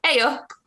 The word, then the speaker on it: Ayo: Ayo. Did I say that right? You're laughing, so I did not. – That was a pretty good shot. So it's like the A out of the word hey.